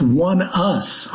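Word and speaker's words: ONEUS: It's pronounced One Us, although they spell it as one word, which is why I didn't understand what was going on.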